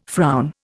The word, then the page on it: frown: Indian